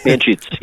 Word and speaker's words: Anschutz: Anschutz, yes.